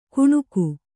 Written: ಗುಣುಗು

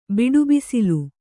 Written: ಬಿಡುಬಿಸಿಲು

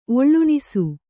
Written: ಒಳ್ಳುಣಿಸು

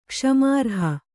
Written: ಕ್ಷಮಾರ್ಹ

